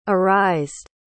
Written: arised

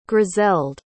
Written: griselde

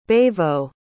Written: bavo

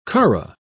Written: curragh

